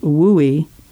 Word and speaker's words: WUI: or WUI, pronounced WUI. As Valiant puts it, the WUI is a beautiful place to live until it goes feral.